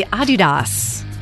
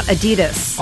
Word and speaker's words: Adidas: Did you know that most of us pronounce Adidas wrong? It's actually supposed to be Adidas. – Really? – Yeah, slight emphasis on the first syllable, not Adidas. – Adidas. – Adidas. – All right. – Yeah, it's German. Just... it's a German company, so.